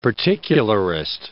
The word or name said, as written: particularist